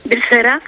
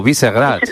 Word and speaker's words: Vyšehrad: Pues creo que es lo que llaman el castillo alto, no sé si se pronuncia Vyšehrad. – Ay, repítenos el nombre del castillo. – Vyšehrad. – Vyšehrad. O algo como, o Visegrad, sí, efectivamente.